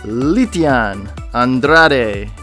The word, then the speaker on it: Lytian Andrade: It is my honor and privilege to introduce our guest today, Lytian Andrade.